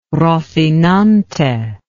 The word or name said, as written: Rocinante